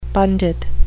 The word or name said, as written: pundit